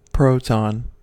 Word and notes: proton: American English

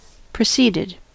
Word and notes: proceeded: American English

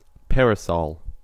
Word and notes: parasol: American English

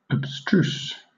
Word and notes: abstruse: British English

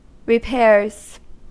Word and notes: repairs: American English

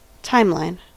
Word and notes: timeline: American English

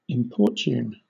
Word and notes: importune: British English

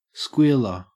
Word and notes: squealer: Australian English

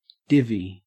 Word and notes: divvy: Australian English

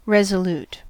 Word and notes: resolute: American English

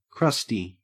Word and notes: crusty: Australian English

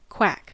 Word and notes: quack: American English

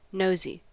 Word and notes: nosy: American English

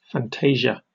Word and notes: fantasia: British English

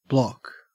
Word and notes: block: Australian English

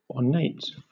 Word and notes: ornate: British English

